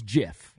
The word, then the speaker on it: GIF: He said the correct pronunciation is GIF.